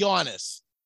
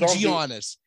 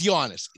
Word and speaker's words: Giannis: If he's in the top 10, say his name right. It's Giannis. It's not Giannis. It's Giannis.